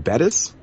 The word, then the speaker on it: Bettis: How do you pronounce her name? Is it Bettis? – Yep.